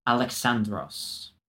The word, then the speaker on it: Alexandros: which was how Greeks pronounced Alexander. And I was told, at least in contemporary Greek, it is pronounced Alexandros.